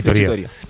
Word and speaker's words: Friggitoria: La última incorporación es la pizzería Friggitoria, ¿Lo he dicho bien ahora? No. Bueno, frigitoría. Fratelli Figurato, en la aldea de Filipinas, número 14.